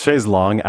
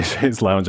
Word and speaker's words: chaise longue: It's chaise longue, actually, but I think because it sounds so weird in English, a lot of Americans say chaise lounge, and that's just become the accepted use.